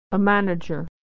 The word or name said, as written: a manager